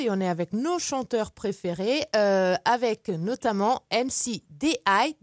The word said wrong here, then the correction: D.Y.: Dis-moi, j'ai bien prononcé? – C'est D.Y.